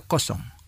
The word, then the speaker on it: kosong: Sin embargo, en conversaciones sobre números, como números de habitación o números de teléfono, el número cero a menudo se pronuncia kosong. Kosong, que significa cero.